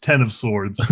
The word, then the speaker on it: X of Swords: X of Swords, which I 100% is supposed to be pronounced Ten of Swords. – Oh. – But the title, it has an X in it, and it's an X-Men book. So don't put an X in it and then tell me not to pronounce the X.